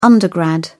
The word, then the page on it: undergrad: British English